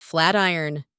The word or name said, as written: flat iron